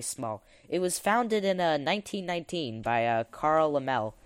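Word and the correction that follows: Laemmle: Actually, I'm going to help you pronounce it. It's Lemley.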